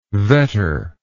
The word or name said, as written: vetter